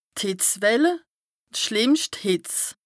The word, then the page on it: d' Hìtzwell, d' schlìmmscht Hìtz: Bas-Rhin; Strasbourg